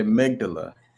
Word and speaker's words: amygdala: the amygdala. How do you say it? – The amygdala.